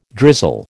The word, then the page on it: drizzle: American English